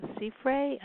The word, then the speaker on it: Siffre: Yeah, the writer says it's a L-A-B-I, Labi, and then Sifre, S-I-F-F-R-E. – Mm-hmm. – Am I pronouncing it correctly?